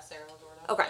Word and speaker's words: Cerro Gordo: And then in May the following year, so 1996, about 100 volunteers searched an area in Cerro Gordo? Is that how you say it? – Is it C-E-R-R-O? – Yeah. – Yeah, Cerro Gordo.